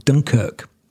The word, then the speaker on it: Dunkirk: Poetically, there's a part of Nottingham where my grandparents lived called Dunkirk. So spelt and pronounced the same as Dunkirk on the north coast of France, with its historical, its place in history.